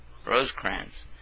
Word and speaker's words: Rosecrans: Rosecrans.